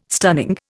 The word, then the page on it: stunning: Indian